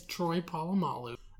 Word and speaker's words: Polamalu: The Muppets came out with this song where they were like intentionally mispronouncing his name. His name is Troy Polamalu.